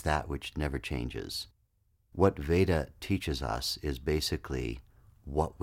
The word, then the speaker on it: Veda: Let's start with the word Veda, V-E-D-A, Veda. Sometimes it's pronounced in India as Veda because Indian people make that V sound a little bit like a W. But for Western purposes, let's stick with Veda.